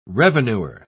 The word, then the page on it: revenuer: British English